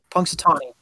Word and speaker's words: Punxsutawney: I'm trying to see because our oh, in the ring uh, How do you pronounce the name of the town? I can't. Punxsutawney. Punk- I think. – Punxsutawney. All right.